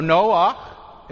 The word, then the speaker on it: Noach: So Noach is how you say Noah in Hebrew.